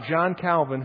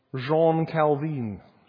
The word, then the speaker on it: Jean Calvin: But you have John Calvin, who is in Geneva. John Calvin is not Swiss. Jean Calvin would probably be the better way to say his name.